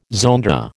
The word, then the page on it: zonda: American English